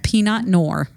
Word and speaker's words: Pinot Noir: Pinot Noir? Is that what you said? – Did I? – Pinot Noir. Yeah. That's how you pronounce that.